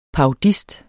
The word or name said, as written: parodist